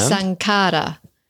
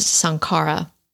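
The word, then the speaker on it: saṅkhāra: So the second part of dependent origination is a Pali word. – And? – Sankara. – Okay. – Often pronounced Sankara.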